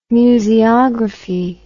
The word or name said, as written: museography